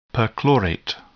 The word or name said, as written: perchlorate